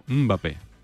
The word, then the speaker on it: Mbappé: Es como que haces el sonidito y luego ya dices Mbappé, pero no es muda.